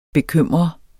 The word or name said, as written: bekymret